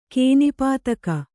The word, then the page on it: ಕೇನಿಪಾತಕ: kēnipātaka